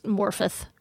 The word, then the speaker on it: Morfydd: Welsh, the double D. It's Morfydd.